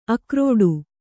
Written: ಆಕ್ರೀಡೆ

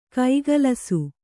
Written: ಕೈಗಲಸು

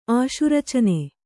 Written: ಆಶುರಚನೆ

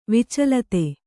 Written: ವಿಚಲಿತ

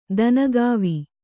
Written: ದನಗಾವಿ

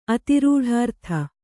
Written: ಅತಿರೂಢಾರ್ಥ

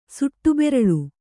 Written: ಸುಟ್ಟುಬೆರಳು

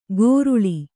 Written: ಗೋರುಳಿ

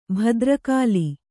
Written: ಭದ್ರಕಾಲಿ

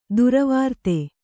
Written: ದುರ್ವೃತ್ತೆ

